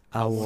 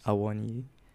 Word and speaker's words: Awoniyi: It's Awoniyi. – So difficult to pronounce. – Awoniyi.